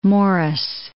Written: Maurice

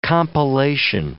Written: compellation

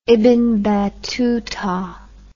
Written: ibn battuta

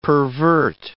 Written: pervert

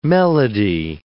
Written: melody

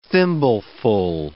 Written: thimbleful

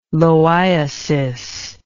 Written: loiasis

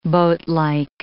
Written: boatlike

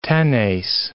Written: tenace

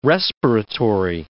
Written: respiratory

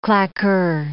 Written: claqueur

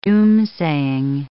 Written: doomsaying

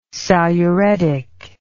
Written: saluretic

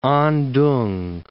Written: Antung

